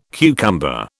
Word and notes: cucumber: British English

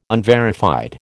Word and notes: unverified: American English

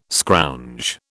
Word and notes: scrounge: British English

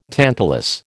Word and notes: tantalus: American English

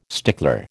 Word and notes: stickler: American English

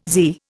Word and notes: zee: Indian

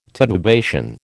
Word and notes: titubation: American English

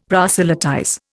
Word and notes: proselytize: Indian

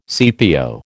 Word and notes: CPO: American English

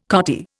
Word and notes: cottier: Indian

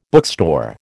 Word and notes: bookstore: American English

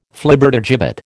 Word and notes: Flibbertigibbet: American English

